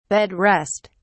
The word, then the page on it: bed rest: British English